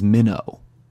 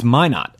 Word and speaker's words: Minot: Minot, which I have been mispronouncing as Minnow. – Right. – It's Minot.